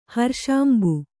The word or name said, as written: ಹರ್ಷಾಂಬು